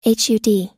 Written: hud

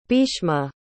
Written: bheeshma

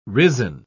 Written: risen

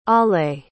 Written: allée

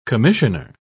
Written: commissionaire

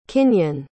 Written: kinion

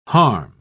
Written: harm

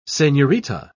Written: senorita

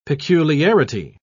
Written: peculiarity

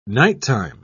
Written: nighttime

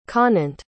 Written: conant

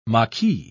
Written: maquis

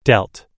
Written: delt

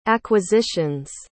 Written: acquisitions

